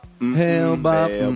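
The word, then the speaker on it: Hale-Bopp: Is that how that's pronounced? – Halibut. – I always thought it was Halibut. Is it Halibut? – Hail Bop.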